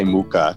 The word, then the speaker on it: Mucha: Alphonse, and his last name is pronounced a million different ways depending on where you're from. I'm going to say Mucha.